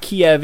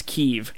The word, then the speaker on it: Kyiv: The other thing that I do want to say is that it's not Kiev, it's Kiev. Kiev is... like, Kiev, spelled K-I-E-V, is the Russian pronunciation and spelling of that city's name. It's actually spelled by Ukraine rules or whatever, is K-Y-I-V, and it's pronounced Kiev, from what I understand.